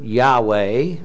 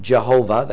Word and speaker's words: Yahweh: It's the word Yahweh. That's the way you pronounce it in Hebrew. Uh, some pronounce it Jehovah. They put J's in for the yods and things like that.